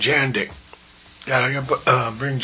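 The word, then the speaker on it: Jandek: Okay, Jandik rhymes with Jack Chick.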